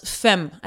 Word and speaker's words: Femme: Yeah, maybe we could have the Tour de France Femme, I think is what it's actually branded. F-E-M-M-E, which I'm probably butchering. My mom would be embarrassed for me. – No, I think you said that right.